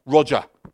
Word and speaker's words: Roger: But no, I listened to interviews and he's a Brit and he's like, Roger. That's how you say it.